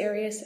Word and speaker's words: Arias: I've heard it pronounced about 7 different ways. I say Arias, as does the rest of my family.